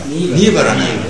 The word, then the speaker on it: Nibbana: Nibbana. Yeah, Nibbana. I was thinking this was something strange. – Nirvana is Nibbana.